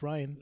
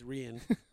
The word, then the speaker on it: Rian: because I actually have HBO now, and I don't I don't need freaking F Rian Moore's uh, HBO login or whatever the heck that fool had. – Yeah, it's Ryan. So, fool. It's Ryan. No, it's Rian.